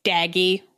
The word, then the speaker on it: Daggy: Daggy rhymes with baggy, an Australian import.